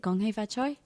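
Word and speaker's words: Gong Hei Fat Choy: Gong hei fa choy? – Gong xi fa cai. – How? – Gong hei fa choy is Cantonese.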